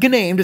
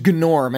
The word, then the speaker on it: Gnorm: It's not gnamed, it's just... – I always want to say gnamed! – A gnome named Gnorm. And yes, you're supposed to pronounce the G's.